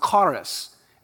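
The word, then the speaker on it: charis (χάρις): You may recall it's a Greek word pronounced charis, and it's the same word used for the word grace in many passages throughout the New Testament.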